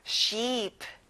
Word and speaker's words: sheep: 이가 두 개라서 길게 내는 겁니다. sheep.